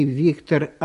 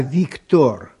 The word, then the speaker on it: Виктор: Во-первых, не Виктор, а Виктор. Во французском языке ударение на последнем слоге.